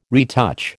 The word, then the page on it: retouch: American English